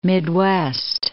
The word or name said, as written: Midwest